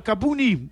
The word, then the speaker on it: Kabuni: Want het is nou wel Kabuni, is het, zo moet ik het uitspreken.